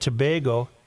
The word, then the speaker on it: Tobago: The island nation of Trinidad and Tobago, the correct pronunciation is Tobago. Have I been saying Tobago? – Who cares? – I was there years ago and asked a local government. I asked a local about the pronunciation, and he said it's Tobago. We are Tobagans, not Toboggans.